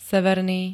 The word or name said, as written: severný